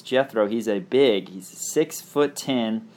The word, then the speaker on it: Gethro: His name is Jethro Muscadin. Jethro, which is spelled G-E-T-H-R-O.